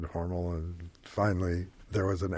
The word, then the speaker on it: Hormel: And my brother, in a stopover at the Cincinnati train station, made a little medallion for my father that said, J.C. Hormel rhymes with normal.